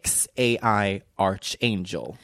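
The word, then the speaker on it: X Æ A-Xii: Jag kan nu härmed stolt meddela att Grimes och Elon Musks barn, alltså XAIA12, uttalas av Grimes som XAI Archangel.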